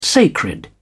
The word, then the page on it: sacred: British English